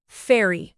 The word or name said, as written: fairy